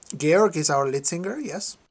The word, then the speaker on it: Georg: I was going to say George, Georg, but that would butcher it. – Yeah, George is, everybody says George internationally, but in his language is Georg in the Austrian German.